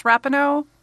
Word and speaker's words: Rapinoe: I think it's Rapinoe. That's how I've heard it pronounced by newscasters.